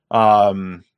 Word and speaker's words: Béziers: Béziers is spelled B-E-Z-I-E-R-S, Béziers. Gotta love the French language, man. B-E-Z-I-E-R-S. Carcassonne. Just spell carcass and then O-N-N-E.